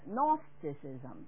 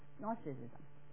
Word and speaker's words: Gnosticism: 咁佢咧就系希腊字嚟嘅， Gnosticism，即系呢个 G 咧就唔读音嘅吓，Gnosticism 意思系智慧。